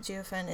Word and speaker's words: Jiufen: was a town in Taiwan called Jiufen. Jiufen, I don't know if I'm saying it right. It's Jiufen.